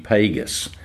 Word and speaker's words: Pegas: Pegas. It's spelled P-E-G-A-S and pronounced like Vegas.